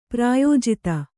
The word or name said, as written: ಪ್ರಾಯೋಜಿತ